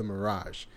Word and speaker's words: Miraj: So he pilots the ship called the Mirage, and it's spelled not how you think. It's spelled M-I-R-A-J.